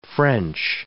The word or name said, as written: French